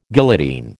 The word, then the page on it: guillotine: American English